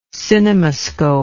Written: cinemascopic

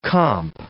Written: comp

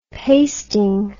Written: pasting

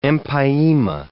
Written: empyema

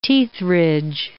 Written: teethridge

